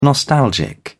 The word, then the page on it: nostalgic: British English